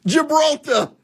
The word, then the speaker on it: Gibraltar: The Hormuz Strait, Malacca, Japan, Gilbertar. Gil, I always say that wrong. Gilbert. You guys know. Gibraltar.